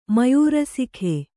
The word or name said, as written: ಮಯೂರಸಿಖೆ